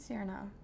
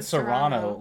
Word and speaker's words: Cyrano: You pronounced it wrong. – What? – It's Cyrano de Bergerac. – I said Cyrano. – You said Serrano, like the pepper. – Yeah, I say things weird, though. – You used the wrong emphasis on the wrong syllable.